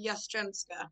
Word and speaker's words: Jastrzembska: Jastrzembska.